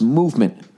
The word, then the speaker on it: MVMT: MVMT Watches, pronounced Movement, was founded on the belief that style shouldn't break the bank.